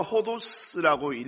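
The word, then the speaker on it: 호도스라고 (ὁδός): 이 헬라어 호도스라고 읽는데요, 이것은 뭐 길 혹은 여행이라는 두 가지 단어를 사용합니다.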